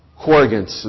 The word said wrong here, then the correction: co-regent: is the term, and I'm probably mispronouncing that. King David will live for a season while Solomon is king as well. It's an overlap. Corrigence. John? Co-regent. Thank you.